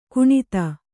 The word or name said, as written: ಗಣಿತ